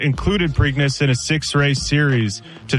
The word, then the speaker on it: Preakness: The next question, Andy, I'm not sure how to pronounce this. The Preakness? Preakness? – The Preakness. Yeah, Preakness.